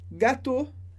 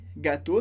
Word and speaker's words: gâteaux: Mas aqui você pode ver gâteau, gâteau, a pronúncia é exatamente a mesma, com e sem x, mas com x indica o plural. E aqui tem nous faisons des gâteaux, nós fazemos bolos. Lembrando que esse D, D-E-S, vai aparecer antes dessa palavra no plural como gâteau, mas a gente não traduz, ou a gente pode traduzir como alguns.